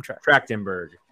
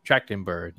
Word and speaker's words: Trachtenberg: Trachtenberg. – Trachtenberg, yeah.